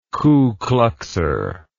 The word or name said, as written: ku kluxer